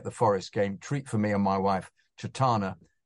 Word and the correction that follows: Chetna: pronounced Ch- Chetna. I'm so sorry. – I'm so sorry. I got it wrong. I'll start again. It was a treat for me and my wife, Chetna, to take.